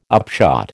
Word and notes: upshot: American English

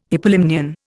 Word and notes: hypolimnion: Indian

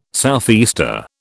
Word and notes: southeaster: British English

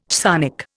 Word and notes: chthonic: Indian

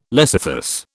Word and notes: lecythus: British English

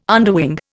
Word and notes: underwing: Indian

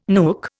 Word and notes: nook: Indian

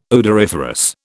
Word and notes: odoriferous: British English

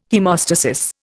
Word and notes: haemostasis: Indian

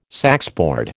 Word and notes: saxboard: American English

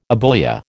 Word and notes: abulia: American English

